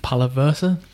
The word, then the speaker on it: Palaversa: Palaversa.